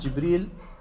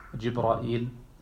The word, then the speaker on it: Jibril: Übrigens Jibril, der Name, man hört manchmal auch, dass man sagt Jibrail oder Jibril oder Jibrail und so weiter. Das sind verschiedene Dialekte, wie dieser Name ausgesprochen wird, der offensichtlich aus dem Nicht-Arabischen stammt.